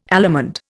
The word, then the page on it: allemande: Indian